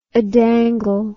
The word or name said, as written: adangle